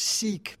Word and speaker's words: Sikh: Usually it's mispronounced as Sikh, but the correct pronunciation is Sikh.